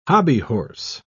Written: hobbyhorse

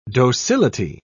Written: docility

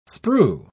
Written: sprue

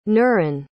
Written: nuren

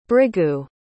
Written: bhrigu

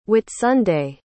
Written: whitsunday

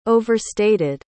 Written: overstated

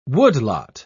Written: woodlot

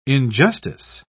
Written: injustice

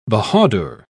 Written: bahadur

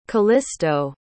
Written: callisto

